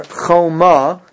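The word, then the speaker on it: Choma (חומה): In 29, the same word is there, Choma, but the Vav is missing. Now, it's still pronounced the same way because you can have an O sound, a Cholam, with a Vav or without a Vav.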